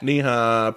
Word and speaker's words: Nehi: Oh, N-E-H-I? – Yeah. – I never knew how to pronounce that. – Knee-high peach.